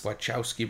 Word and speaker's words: Wachowski: Wachowski Brothers. – Wachowski Brothers, yeah.